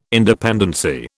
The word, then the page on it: independency: British English